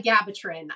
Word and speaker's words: Vigabatrin: Sometimes that's referred to as Vigabratin. I'm worried I'm going to mispronounce that one. It's a mouthful. – I think that one's by Vigabatrin.